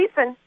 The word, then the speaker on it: Thiessen: Is it Thiessen? Yes, yeah, Thiessen.